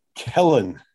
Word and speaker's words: Kellen: Kellen. – Kellen? Yes. Kellen. Okay. Just like Kellen. Ellen with a K.